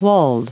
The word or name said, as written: walled